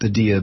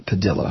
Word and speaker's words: Padilla: And like I mentioned, Jose Padilla, and I used to pronounce it Padilla, but I was told that this family pronounces it Padilla.